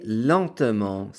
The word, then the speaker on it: lentement: And in this situation, the ENT is pronounced as a nasal EN, which sounds like en, as in enfant for child. So speak slowly, parlez lentement, s'il vous plaît.